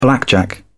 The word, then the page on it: blackjack: British English